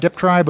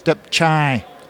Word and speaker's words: Deptry: most people say Deptry, but in fact it's Deptchai. Chai like a CH, but it's a TR.